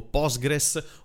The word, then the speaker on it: Postgres: Innanzitutto è stato rilasciato PostgreSQL o Postgres o PostgreSQL, non so come voi lo pronunciate, io lo pronuncio Postgres.